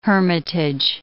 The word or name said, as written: hermitage